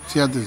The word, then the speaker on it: Seattle: está Seattle, o no sé cómo se pronuncia, en inglés también sí, Seattle.